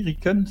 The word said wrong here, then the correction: Ekiden: C'est R. – Non, Ekiden, Equiden, pardon.